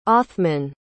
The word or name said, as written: othmann